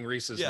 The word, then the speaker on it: Reese's: Yeah, Reese's Pieces is proof that you're saying Reese's wrong from the Reese's Cup.